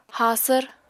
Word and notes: haasvr: Apatani

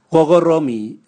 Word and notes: gogoromi: Raga

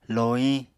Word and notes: lohiñ: Apatani

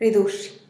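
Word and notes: ridux: Teotitlán del Valle Zapotec

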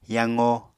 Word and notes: yaqo: Apatani

